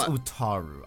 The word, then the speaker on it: Utaru: They faced little resistance in raiding the native Urutu. I totally butchered that. – I believe it's Utaru.